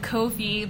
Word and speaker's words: Ko-fi: K-O-F-I. And then it's like, want to buy me a coffee? – Oh, and can you explain to us older folks what these, what does it mean? – Essentially, coffee is like, or how you pronounce it, Ko-fi.